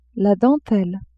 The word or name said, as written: dentelle